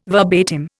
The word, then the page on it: verbatim: Indian